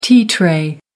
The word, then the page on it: tea tray: American English